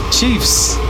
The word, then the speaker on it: Secret Chiefs: Chefs? Are you determined to like just mispronounce every single artist? The Secret Chefs. – Chiefs. – Oh, I've never heard of that. – Chiefs. – Secret Chiefs 3.